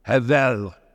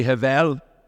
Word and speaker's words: hevel: And the word is hevel. Hevel. Can you say hevel? It's like clearing your throat, you know, hevel.